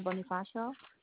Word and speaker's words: Bonifacio: Angelica Bonifacio, and the zip code is 94545.